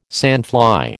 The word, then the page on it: sandfly: American English